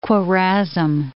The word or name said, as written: Khwarazm